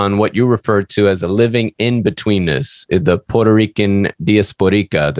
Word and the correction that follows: diasporican: Yeah, diasporica, but it's really diasporican.